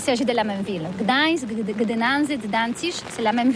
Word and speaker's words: Gdańsk: très facile pour des étrangers, mais remarque bien que la langue polonaise, c'est la langue où on prononce chaque lettre qu'on écrit. C'est pourquoi on dit Gdańsk.